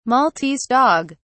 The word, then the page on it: maltese dog: British English